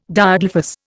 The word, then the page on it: Diadelphous: Indian